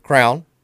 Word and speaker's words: crayon: How do you say crayon? – Crayon, yeah. – Yeah. – I family members who say crayon. – What's another pronunciation for crayon? – Crown. – Yeah.